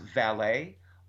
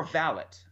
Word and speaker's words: valet: So, for example, a word like V-A-L-E-T, which we could pronounce valet or valet. Mm-hmm. And if you ever watched Downton Abbey, they said valet.